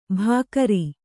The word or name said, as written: ಬಗರಿ